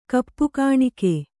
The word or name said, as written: ಕಪ್ಪುಗಾಣಿಕೆ